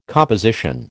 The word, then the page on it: composition: American English